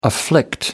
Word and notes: afflict: American English

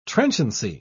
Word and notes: trenchancy: British English

